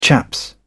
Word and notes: chaps: British English

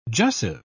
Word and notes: jussive: British English

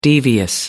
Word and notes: devious: American English

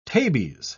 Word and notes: tabes: British English

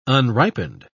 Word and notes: unripened: British English

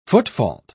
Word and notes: footfault: British English

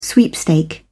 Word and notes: sweepstake: British English

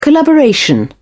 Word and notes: collaboration: British English

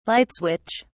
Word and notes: light switch: British English

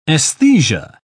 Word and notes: aesthesia: British English

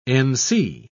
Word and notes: nc: British English

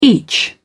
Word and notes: each: British English

